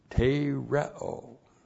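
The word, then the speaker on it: Tereo (τηρέω): Tereo. T, long E, R, E, long O. Tereo.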